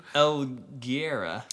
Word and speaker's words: Helguera: So, Ivan Helguria. – That is unconfident pronunciation. – Helguera. – How is it spelled? – H-E-L-G-U-E-R-A. – L-G-U-E-R-A? – Yeah, Helguera.